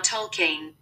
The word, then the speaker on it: Tolkien: How do you say J.R.R. Tolkien? – That's pronounced J.R.R. Tolkien. – Tolkien. – You were saying it right.